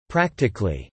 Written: practically

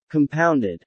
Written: compounded

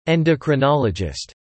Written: endocrinologist

